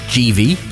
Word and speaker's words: Givi: By the way, it's GV, but that's fine. I'll let you away with it. – GV? I've never heard it pronounced, ever. So GV luggage. I suppose that makes sense. It is Italian, I believe, isn't it? – Is that from our friends at Eurobike Wholesale?